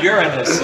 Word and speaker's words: Uranus: Uranus. It's the correct pronunciation.